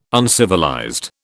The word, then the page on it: uncivilized: British English